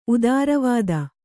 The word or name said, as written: ಉತ್ತರವಾದಿ